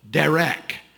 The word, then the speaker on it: derech: This is a Hebrew word that is pronounced close to this, derech. It is often translated journey, but it can be translated a street or a road.